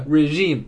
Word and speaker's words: regime: 比如说 Britain 就 W-E-N threatened 威胁 to break off diplomatic relations with the regime 啊，那个 W-E-N 威胁来断绝 break off 断绝 diplomatic 外交的 relations 关系 with the regime，这单词读成 regime 啊，政权，有指啊非公开选举的那种政权，比如说像北朝鲜啊，还有一些国家我就不方便提。